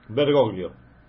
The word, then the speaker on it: Bergoglio: ¿Bergoglio? – Bergoglio.